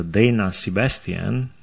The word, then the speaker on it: Dana Sebastian: De fapt, Dana Sebastian este americană, este din Statele Unite, se pronunță Dana Sebastian, numele ei. Seamănă foarte mult cu un nume românesc, dar cu toate acestea nu este româncă, este americană, americană.